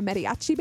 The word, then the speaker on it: mariachi: She said she walked down the aisle to a mariachi band, nice.